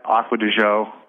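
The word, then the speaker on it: Acqua di Giò: I actually had Aqua di Gio. Is really how you pronounce it? – You're pronouncing it correctly.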